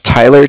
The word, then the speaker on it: Tyler: My name is Tyler, T-Y-L-E-R, mm-hmm. – And I'm calling with Cardiff. It's spelled C-A-R-D-I-F-F, as in Frank Frank.